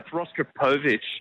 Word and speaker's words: Rostropovich: And the horse that came last in the race, Rostropovich, how do I pronounce it? – It's Rostropovich.